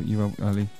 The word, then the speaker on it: Uly: His name's Uly, U-L-Y, Uly. Uly, I don't know how to pronounce it. How would you go for Uly?